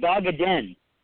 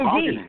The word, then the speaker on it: Van Wagenen: We pronounce it Vogeden. There's no Vogenen. – D. There's no D.